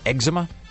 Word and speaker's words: eczema: Is it pronounced eczema? – Yes.